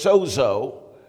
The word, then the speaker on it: sozo: The Greek word pronounced sodizo, but we pronounce it in the English sozo, but it means to be made whole, to be healed, spirit, soul, and body, to be made whole, to be made well.